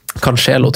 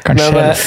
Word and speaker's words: Cancelo: Cancelo droppa vi. – Cancelo, tror jeg det uttales. – Kan, ja, riktig. Sorry. – Cancelo.